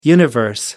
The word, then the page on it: universe: American English